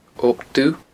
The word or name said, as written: Ob Dubh